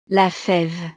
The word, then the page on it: la feve: French